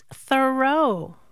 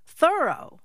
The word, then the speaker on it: Thoreau: All this time, I thought his name was pronounced Thoreau, but no. – How is it pronounced then? – Thorough.